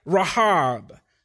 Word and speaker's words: Rahab: Rahab, actually, if you want to pronounce it right.